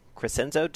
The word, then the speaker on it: Crescenzo: Crescenzo, did I say that right, Neil? – That's right.